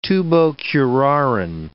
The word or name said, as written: tubocurarine